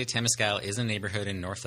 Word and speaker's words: Temescal: I always tell people it rhymes with tennis gal. So if you want to know how to say it, just think Temescal.